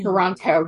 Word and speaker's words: Toronto: I think when you say Torontonian, it's the only time that someone from here will pronounce the second T. Otherwise, if you want to seem like you're a local and blend in with everyone, just call it Toronto. Like it's just spelt with a bunch of N's.